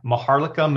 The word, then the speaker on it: Maharlika: Yeah, Maharlika Manila.